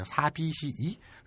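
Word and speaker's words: 사비시이 (寂しい): 사비시이. 그래서 발음을 사비시 뒤에를 좀 끌어줘야 돼요. 장음 처리해서.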